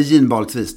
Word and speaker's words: ginbalksvis: I blått fält, en ginbalksvis, uttalar man det så? Eller ginbalksvis? G-I-N-B-A-L-K-S. – Vi säger ginbalksvis, detta ginbalksvis.